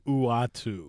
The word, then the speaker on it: Uatu: Yeah, I want to butcher his name. Uatu? Uatu the Watcher? – Uatu. – Uatu. I think that's right. Uatu. That's one I never knew how to pronounce.